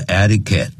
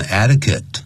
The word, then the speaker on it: etiquette: 嗯哼， etiquette 这个字我们教过，按照这个字典的发音是 etiquette。但实际很多外国人他念成 etiquette，像 the international，不是前面不需要加的 international etiquette，就是指的是国际礼仪啊。